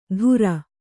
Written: ಧುರ

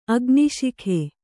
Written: ಅಗ್ನಿಶಿಖೆ

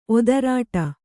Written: ಒದರಾಟ

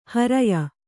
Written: ಹರೆಯ

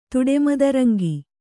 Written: ತುಡೆಮದರಂಗಿ

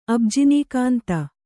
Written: ಅಬ್ಜಿನೀಕಾಂತ

